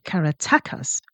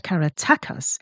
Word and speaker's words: Caratacus: Caratacus, and there have been many, many ways offered in pronouncing his name over the centuries, but modern linguistic history points at Caratacus as more aligned with the original pronunciation rather than the classical Caratacus.